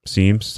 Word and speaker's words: Symmes: In 1818, John Seames Jr., I think that's how you say his name, S-Y-M-M-E-S, Seames.